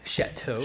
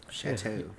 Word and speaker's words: chateau: And you could have like your own chateau. How do you say that word? – Chateau. – Chateau. – Oh, so I said it right? – Chateau.